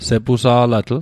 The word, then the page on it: sepusa'latl: Mi'gmaq